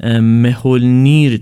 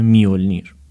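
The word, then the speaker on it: Mjölnir: اون حرف جی که داخل این کلمه هست در زبانهای جرمانیک و اسکاندیناوی معمولا به شکل یه تلفظ میشه و اون زبانهای اسپانیولی هستن که جی رو به شکل ه میخونن. پس در حقیقت تلفظ درست این اسم میشه میولنیر.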